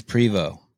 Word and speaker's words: Prevost: And your last name is Prevost. – Yep. It's a silent ST. Uh, it's French.